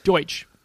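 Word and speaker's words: Deutch: But you know what you need with an ancient premise movie is two young actors with ancient sounding names, Zoe Dutch and Glenn Powell. – Deutsch. – Is that how it's said? – That's right.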